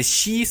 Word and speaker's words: Qi: Now, I'm talking about the SHI standard, spelling QI, but pronunciation is SHI, because it focuses on power.